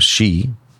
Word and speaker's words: Xi: President Xi, am I pronouncing it correctly? – I think so, yeah. – President Xi.